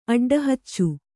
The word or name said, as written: ಅಡ್ಡಹಚ್ಚು